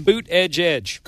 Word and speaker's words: Buttigieg: How do you pronounce that name again? Boot Edge Edge. – Okay, thank you.